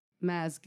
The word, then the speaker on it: Mads: Dr. Maz Gilbert, and I pronounced that one right because Maz Mikkelsen is one of my favorite actors, and it is spelled the same way, M-A-D-S.